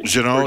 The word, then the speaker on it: Jeannot: Tanner Jeannot, if I pronounced it right. – Jeannot, yeah.